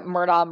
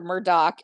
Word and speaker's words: Murdaugh: Um, but so I went in completely blind um, on the Netflix documentary, The Murdaugh Murders. And people pronounce it different. So it's either Murdaugh, Murdaugh, or Murdoch.